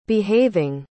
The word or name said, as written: behaving